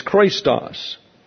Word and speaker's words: chrestos: This is Christos. This is not Christos, it's Christos. That's a eta there. C-H-R-E-S-T-O-S. Pronounced like a long English A. Christos.